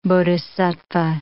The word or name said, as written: bodhisattva